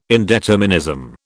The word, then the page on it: indeterminism: British English